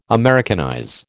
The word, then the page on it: Americanize: American English